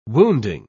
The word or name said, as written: wounding